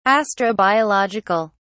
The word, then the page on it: astrobiological: British English